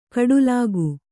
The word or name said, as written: ಕೋಟಲಿಗ